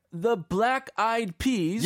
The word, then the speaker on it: Black Eyed Peas: y 자, here we are, the Black Eyed Peas.